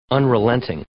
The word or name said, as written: unrelenting